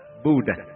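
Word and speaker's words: Bú Đà (不大): Bất Đại phát âm là Bú Đà, không to lớn.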